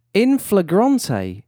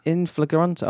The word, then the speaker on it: in flagrante: Is that how you say it? I don't know that. – In flagrante. I googled the little sound clip of it.